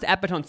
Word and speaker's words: epitome: But then I thought I said epitone and it was actually epitome.